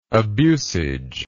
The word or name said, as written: abusage